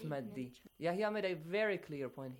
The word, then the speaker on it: Yahya: Like Yahya, ye is not pronounced.